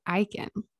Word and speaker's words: Aeikens: All right, Dr. Brooke Aeikens.